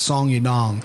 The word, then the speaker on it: Song Yadong: I believe he's pronounced Song. You've been pronouncing it right. Song Yadong is what he gets called on the UFC broadcast.